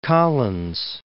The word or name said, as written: Fort Collins